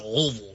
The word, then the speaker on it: Louisville: We're at Louisville. Not Louisville. – You gotta say it like you have peanut butter stuck in the roof of your mouth. Louisville.